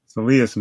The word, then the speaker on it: soleus: Soleus muscle.